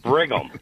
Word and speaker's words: Brigham: No N, Jack. There's no N in Brigham. It's not Brigham. It's Brigham. – So you're saying it is Brigham? – No, it's B-R-I-G-H-A-M. The H is silent.